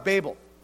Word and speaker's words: Babel: But think about the Tower of Babel. So the Tower of Babel or Babel, you can pronounce it both ways.